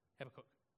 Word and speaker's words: Habakkuk: The other thing that happens is when we identify these things, it's not about, let me tell you a story in the book of Habakkuk, Habakkuk, depending on how you want to pronounce it.